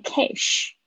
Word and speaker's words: cash: 好，那我们来讲这个现金的 cash，cash，注意一下你们的 a 跟 sh 的发音，顶多就是这两个发音很容易会念错，不要念 cash。不是 a 是 a cash，然后呢，有些同学的 sh 的声音被我纠正过，不要再念成 x 的声音了哦。